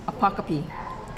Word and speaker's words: apocope: My sponsor is Apocope. – Apocope? – Apocope. – Can you spell that? – It's really hard to say. A-P-O-C-O-P-E.